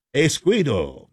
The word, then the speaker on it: escudo: Escudo. I'm hopefully pronouncing that correctly. Escudo. There we go. That's more like it.